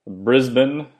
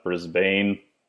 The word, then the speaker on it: Brisbane: We then drove down to Yamba, which is down on the coast, on the eastern side, south of Brisbane, which I guess is how you're supposed to pronounce it, not Brisbane.